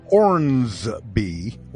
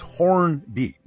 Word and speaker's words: Hornby: It's not Hornsby, like, you know, the musical artist Bruce Hornsby. It's Hornby, no S. – Right. – Hey, everybody. Listen close here now. Hornby is spelled H-O-R-N-B-Y.